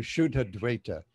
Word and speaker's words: Shuddha Dvaita: So the Dvaita Dvaitavad was a sort of dualistic monism, the Shuddha Dvaita, this is also Shuddha Dvaita with the, this is actually Shuddha Dvaita. So it doesn't have the long A.